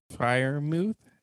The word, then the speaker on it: Freiermuth: For me, Pat Freiermuth.